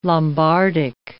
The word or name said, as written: Lombardic